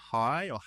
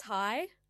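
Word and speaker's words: Haai: So, I mean, also, I really love that the DJ, the producer, Hay, I don't know how you say it, it's H-A-A-I. I've never learned how to say. – Yeah, I think it's Hay.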